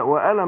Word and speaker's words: وأنا: الهمزة أنا وأنا معكم، عشان الهمزة بتسقط في وسط الكلام، الألف هنا ما بينطقش. وأنا معكم، مش وأنا معكم، لا وأنا معكم.